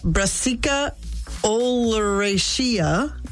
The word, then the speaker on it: Brassica oleracea: Depending on how it's grown, the Brassica oleracea nailed it.